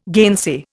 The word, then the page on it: gainsay: Indian